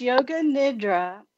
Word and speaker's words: Yoga Nidra: But so first, what is Yoga Nidra? I don't know if I'm pronouncing that correctly. But... – It's Yoga Nidra, and it's yogic sleep.